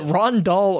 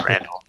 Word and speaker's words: Randall: Is it Rondahl or is it Randall? – It's Randall. – It's Randall. – You put the emphasis on the wrong syllable.